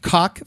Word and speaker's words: caulk: Cock for your household goods, you know, when you're sealing off the edges, is C-A-U-L-K. – Oh, caulk. – Caulk. – I think it's still pronounced the same. – Oh, it's still cock. – Right. Just like when you say, she's fat, it's P-H-A-T.